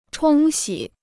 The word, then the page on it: 冲洗: chōng xǐ